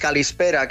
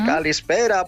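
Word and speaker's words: calispera (καλησπέρα): Mira, cuando te digo buenas tardes, calispera, qué sonido más bonito, y es súper fácil de decir, calispera para caló.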